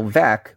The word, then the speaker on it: Veeck: Bill Veeck, which is the correct pronunciation of his name, Thank was you.